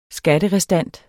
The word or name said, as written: skatterestant